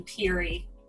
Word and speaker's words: Perry: The last name is actually Peary. – Peary? – Peary.